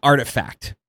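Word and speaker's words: RTFKT: And so like those two worlds came together yesterday and Nike announced it acquired an NFT sneaker company called Artifact. It's spelled like RT. There's no vowels in there, right?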